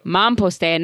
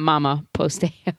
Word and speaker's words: Mamposteo: We might be saying it incorrectly. Mamposteo, not mama posteo. Mamposteo.